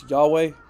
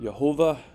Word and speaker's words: Yahweh: You can see here on my screen, Lord, it's the Hebrew H3068, which is, we usually pronounce Yahweh. Some people pronounce it Yehovah, or other things.